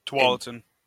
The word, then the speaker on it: Tualatin: Tualatin. – Tualatin, okay. And Tigard. – Trigard? – It's not Tigard. It's T-I-G-A-R-D.